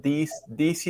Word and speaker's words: this: Um, yo he visto muchos alumnos pronunciarlo como si fuera una D en español. This, this, that. Y luego queda como un acento cuando uno lo pronuncia, ¿no? This, this is a book. – This. – And we have to, de nuevo, hay que sacar un poquito más la lengua para afuera y hacerlo sonar más fuerte, ¿no?